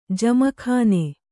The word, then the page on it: ಜಮಖಾನ: jamakhāna